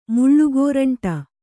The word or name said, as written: ಮುಳ್ಳುಗೋರಂಟಿ